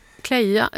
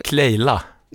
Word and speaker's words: Clélia: C-L-E med en apostrof över E-t. L-I-A. – Kleja, eller? – Kleila.